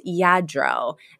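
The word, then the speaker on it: Lladró: And Frida's like, you know, I do have all this Lardo China that needs dusting. And Sylvia's like, it's pronounced Yadro.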